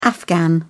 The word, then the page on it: afghan: English; British English